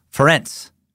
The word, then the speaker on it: Ferenc: Frank was my grandfather's name. It's like the Hungarian equivalent of Frederick. Okay. It's actually pronounced like Terence with an F, like Ferenc.